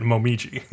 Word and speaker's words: Momiji: I always have trouble with his name because I want to say Momiji. – That is how you say it, Momiji. – But it looks like Moiji. – No, it doesn't. There's an M.